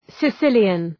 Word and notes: Sicilian: sı’sılıən

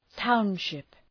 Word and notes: township: ‘taʋnʃıp

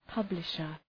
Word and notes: publisher: {'pʌblıʃər}

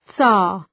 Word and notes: tsar: zɑ:r

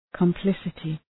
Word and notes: complicity: kəm’plısətı

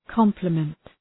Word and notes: complement: ‘kɒmpləmənt

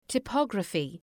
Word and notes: typography: taı’pɒgrəfı

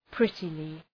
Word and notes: prettily: ‘prıtılı

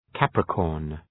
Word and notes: Capricorn: ‘kæprıkɔ:rn

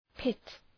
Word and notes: pit: pıt

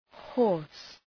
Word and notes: hoarse: hɔ:rs

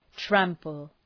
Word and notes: trample: ‘træmpəl

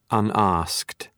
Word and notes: unasked: ʌn’ɒskt

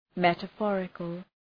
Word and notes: metaphorical: ,metə’fɔ:rıkəl

